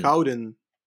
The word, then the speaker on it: Gouden: G-O-U-D. Gouden. – But yeah, that's a problem because, like, I'm West Flemish, which means that I pronounce every G like an H, and we get laughed at for the entire country because of that. So for me, it's Gouden, but it's technically Gouden.